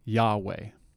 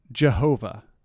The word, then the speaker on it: Yahweh: and it is the name Yahweh, or Jehovah. Those are actually two different ways to pronounce the same Hebrew word.